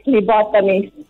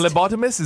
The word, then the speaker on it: phlebotomist: So can you please tell us how it's said? – Okay, I'm a phlebotomist. – Phlebotomist. – Phlebotomist, is that correct? – Oh, wow. – Phlebotomist, yes.